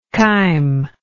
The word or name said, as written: chymous